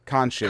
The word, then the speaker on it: Khonshu: uh, Kenosha, uh, Keno, um, I can't even say his name. Khonshu. – Khonshu.